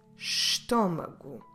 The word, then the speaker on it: estômago: The word for stomach is estômago.